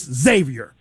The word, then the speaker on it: Xavier: It's not X, it's Xavier. – Xavier. – Thank you. – Mm-hmm. So our mom continue your story. – Our mom went to Xavier. – Thank you. That's how you say it.